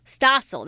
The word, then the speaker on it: Stossel: And as mentioned there, that was Strassel, right? Was that John Strassel? It sounded like him. Stossel. That's how you say it?